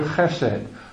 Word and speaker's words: chesed: but it is the word chesed, which is spelled C-H-E-S-E-D in English letters.